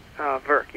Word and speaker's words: Virk: Virk, yep.